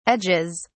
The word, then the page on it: edges: British English